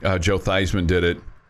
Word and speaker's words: Theismann: His name was Joe Theismann at Notre Dame, and he changed his name to rhyme with Heisman because mm-hmm.